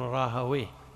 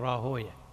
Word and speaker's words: راهويه: اللغويون يقول راهويه المحدثون يقولون راهوية، اللغويون يقولون سيبويه، المحدثون يقولون سيبويه، اللغويون يقولون نفطويه، المحدثون يقولون نفطوية. ليش؟ لأنهم قرأوا في بعض الكتب أن من أسماء الشيطان ويه، فيكرهون نطق الاسم على اسمه فغيروه فخلوا راهوية سيبوية.